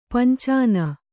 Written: ಪಿಂಚನಿ

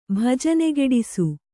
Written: ಭಜನೆಗೆಡಿಸು

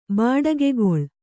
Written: ಬಡಿಗೆಗೋಲು

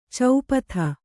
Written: ಚಪಾತಿ

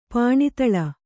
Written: ಪಾಣಿತಳ

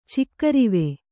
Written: ಚಿಕ್ಕರಿವೆ

